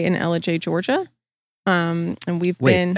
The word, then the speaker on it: Ellijay: L-I-J. – Okay. – Ellijay.